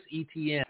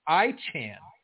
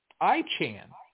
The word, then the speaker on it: Etienne: Let's move on to Kareem Hunt, Adam Thielen, Travis Etienne. I heard that Travis, you know, everybody on TV pronounces it Etienne, but actually it's pronounced I-chan. I-chan. I read on one of the posts.